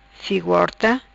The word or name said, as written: Figo Horta